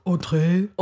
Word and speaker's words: Audre: Is it Audre? – Audre. – Audre Lorde. – Audre Lorde. Sounds really good.